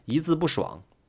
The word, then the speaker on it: 一字不爽: 包括我们的节目的名字“ 一字不爽”，为什么我们会读成“ 一字不爽”？一明明是一声，但是在放到成语里面的时候，它会读成了二声。